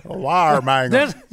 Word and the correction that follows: Weimaraner: What is the correct pronunciation of them two words? – Weimaraner. A Weimaraner.